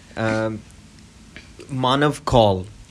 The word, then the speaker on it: Manav Kaul: Manav Kaul.